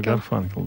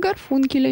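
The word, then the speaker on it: Garfunkel: Вы неправильно произносите имя Гарфункель, написали нам. Гарфункель — это был такой фильм «Семеро смелых». Вот там был Гарфункель, а у нас, Гарфанкель. Я думаю, Гарфанкель, да.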